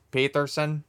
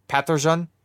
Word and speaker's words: Pettersson: I like North American way of saying Peterson. And but then everybody's like, well, is it actually pronounced Pettersson? And he's like, Pettersson. And like, okay, so it is Pettersson. He's like, yeah, but you can say Peterson.